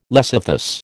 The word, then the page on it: lecythus: American English